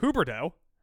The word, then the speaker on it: Huberdeau: Why can't I say his name? Huberdeau. – Yeah. You're putting the R on the wrong end of the D.